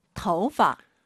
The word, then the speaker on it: 头发: 头发. – 오유는 어우예요. 토우 하지 마시고 터우예요. 이성경성이니까 아하, 头发. 재밌는 건 이 파를 사성어로 발음을 정말 많이 해요.